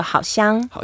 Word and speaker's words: 好香: You say, oh, 好香. – 好香. – Yeah, smells so good. And never say 好臭. – Never. I would never say that. – Yeah, never say 好臭. So 好香.